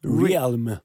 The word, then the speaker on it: Realm: Svårt ord att uttala det här. Realm. – Realm.